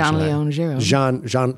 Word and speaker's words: Jean-Léon Gérôme: Jean-Léon Gérôme.